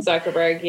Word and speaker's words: Zuckerberg: is that how you say his name? – Zuckerberg, yeah.